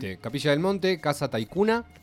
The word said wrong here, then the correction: Taykhuma: Taikuma. – Taikuma. – Casa Taikuma, que también lo pueden buscar, se escribe T-A-Y-K. H-U-M-A, lo pueden buscar en Facebook.